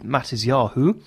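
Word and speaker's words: Matisyahu: This is from an artist from recent times in the last couple of years called Mattis Yahu, which is the Ashkenazi Hebrew or Yiddish pronunciation of the biblical name of Matthew.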